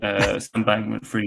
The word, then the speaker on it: Sam Bankman-Fried: Sam Bankman-Fried. – Even though we just talked about this. – Sam Bankman-Fried.